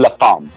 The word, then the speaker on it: La Femme: It's pronounced La Femme.